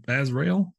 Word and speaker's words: Azrael: How do you say Azrael? It's Azrael as it gets.